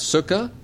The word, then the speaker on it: Sukkah: This first one is Sukkah City. That is, I'm pronouncing it correct. Sukkah.